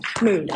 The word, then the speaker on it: Mood: Mood, M-O-O-D.